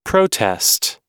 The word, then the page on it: protest: American English